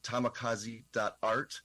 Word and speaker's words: tamikaze.art: And that is tamikaze.art. And it's spelled like kamikaze, but with a T. So that's tamikaze.art.